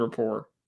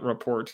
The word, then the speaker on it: rapport: There, there's they they they have they have a, really good rapport. Rapport is how you say it, not report.